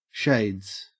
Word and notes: shades: Australian English

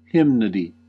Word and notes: hymnody: American English

